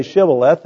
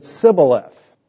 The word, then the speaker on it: Shibboleth: They didn't say Shibboleth, they said Sibboleth.